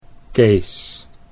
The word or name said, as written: Kees